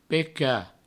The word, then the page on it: pecar: peca; provencal